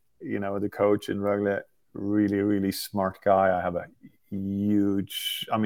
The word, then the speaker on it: Rögle: Actually, I think, you know, first and foremost, it's pronounced Rögle. You said Leksand. Great. You didn't say IF. So don't pronounce the BK as well.